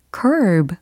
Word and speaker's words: curb: 네, 이거 특히 curve 하고 발음 차이를 잘 기억하셔야 됩니다. 그죠? C-U-R-B라고 했으니까 curb. 그런데 비교할 발음은 C-U-R-V-E 였어요. 그래서 curve. 그쵸? 발음 차이 느껴지시죠? 네, 우리가 연습한 표현은 curb 였습니다.